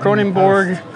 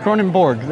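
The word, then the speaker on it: Kronenbourg: Kronenborg, Kronenborg, Kronen. Yes, it's Kronenborg.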